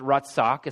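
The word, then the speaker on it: ratzach (רצח): But what's interesting is that the word that's used here in the sixth commandment is a Hebrew word, and it's pronounced ratzach, essentially.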